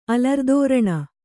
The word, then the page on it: ಅಲರುದೋರಣ: kannada